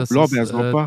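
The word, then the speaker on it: Blaubeersorbet: So wird es richtig ja. ausgesprochen, ja. Genau, Blaubeersorber.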